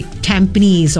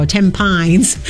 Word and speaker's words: Tampines: Tampines or Tampines, as a foreigner would pronounce it sometimes.